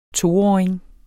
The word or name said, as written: toåring